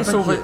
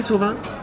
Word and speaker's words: Sauvé: André Sauvé. – Je vais aller voir. Comment ça s'écrit, Sauvin? – Sauvé? Non, Sauvé, S-A-U-V-E, accent aigu.